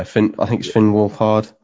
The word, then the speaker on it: Finn Wolfhard: Yeah, Finn Wolfhard.